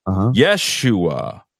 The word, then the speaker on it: Yeshua: She says, I'm on my phone, so I can't get into the chat, but his name is Yeshua. – Yes. – She spells it Y-E-S-H-U-A and or how they want to spell it, but she wanted to get her yes.